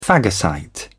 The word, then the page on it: phagocyte: British English